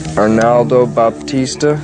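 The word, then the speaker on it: Arnaldo Baptista: Arnaldo Batista, is that how you pronounce his name? – That's it.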